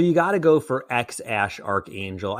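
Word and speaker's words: X Æ A-12: So it's X, A-E, A12 is the baby's name, but you don't pronounce it X, A-E, B. X A E A 12. You pronounce it X Ash, and then what's the last part is also code for something, right? – So A-12 is thought to be in reference to the CIA aircraft Lockheed A-12, which was codenamed Archangel. So this kid's name is apparently pronounced as X Ash Archangel.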